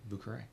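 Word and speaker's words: Vieux Carré: And Vucare. – Perfect eggnog. – Which, if you're looking it up, is not spelled like it sounds because it's French. – Yeah, no, it is. – It is French. – V-I-E-U-X-C-A-R-R-E.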